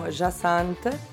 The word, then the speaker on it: Jacynthe: Allora, su Spotify mi chiamo Jasant, che si scrive J-A-C-Y-N-T-H-E.